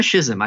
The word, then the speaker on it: schism: Now that there has been this kind of right wing schism, however it's pronounced, is it schism or schism? I always have this argument. – It's actually schism. – No, like there's no CH at all.